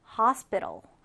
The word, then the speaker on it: hospital: 이때 우리가 발음할 때 hospital 이렇게 tal이라고 발음하는 친구들이 있어요. 그런데 발음교를 잘 보면 그냥 t와 l밖에 없죠. 그래서 hospital. hospital 이렇게 발음을 해 주시면 되겠습니다.